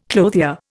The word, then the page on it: clothier: Indian